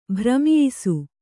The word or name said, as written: ಭ್ರಮಿಯಿಸು